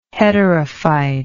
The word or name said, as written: heterophyte